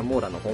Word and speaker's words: MOURA: なんかですね、僕らなんかその詩の朗読役者さんにお願いしたんですけどね、ご本人の詩がね、インターネット上にあるのかってことでですね、ちょっと悔しく思ってるんですけども、サマーさんがどんな声の人なのか気になる方は、ぜひ講談社モーラのホームページまで足を運んでみてください。講談社のホームページから一応いけるんですけども、講談社 MOURA と書いて MOURA と書いてモーラ、こちらです。